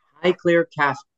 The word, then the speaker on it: Highclere: So I have, it's pronounced Highclere, right? – Highclere Castle, yes.